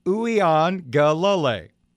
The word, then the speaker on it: Uiagalelei: DJ's last name, according to Syracuse, is pronounced ooh, e, on, ga, la, lay.